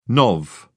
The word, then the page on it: nov: British English